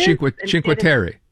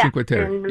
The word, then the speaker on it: Cinque Terre: Cinque Terre. – Yeah. – Cinque Terre.